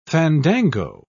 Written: fandango

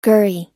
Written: gurry

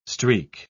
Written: streak

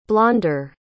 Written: blonder

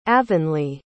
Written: avonlea

